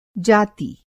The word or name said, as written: जाती